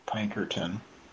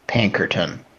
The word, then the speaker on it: Pinkerton: Yes, Pinkerton. – Okay, so J-A-C-K? – Mm-hmm. – Okay, and then the last name? – Pinkerton. – Yeah. Could you spell it? – P-I-N-K-E-R-T-O-N.